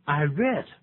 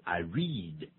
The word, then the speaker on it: read: I read 不要念成 I read，因为这已经发生在过去的状态，我刚才把它念反了，所以这是一个过去时。